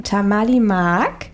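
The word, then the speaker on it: Tamally Maak: Und zwar heißt der Song Tamali Mark von Amir Diab. – Richtig, super ausgesprochen.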